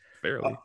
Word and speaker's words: Farley: At number three, I've got the guy that you mentioned, Caleb Farley. Or is it Farley? Do you know? It's spelled Farley. – No, it's Farley. That's how I say it.